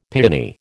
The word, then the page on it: piani: American English